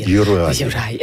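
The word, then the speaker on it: Juraj: Personnellement, moi, j'ai de la difficulté avec Yurav Slavkovski. – Yurai.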